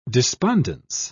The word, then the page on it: despondence: British English